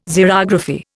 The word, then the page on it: Xerography: Indian